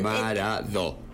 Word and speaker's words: Maradó: Y arrancamos escuchando a Los Piojos cantándole a qué personaje, a Maradó. – Claro, así, con acento en la O.